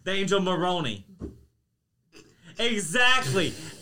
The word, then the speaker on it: Moroni: And then at one point in 1823, he saw this angel, the angel Moroni or Moroni, M-O-R-O-N-I. I'm not trying to pronounce it like moron.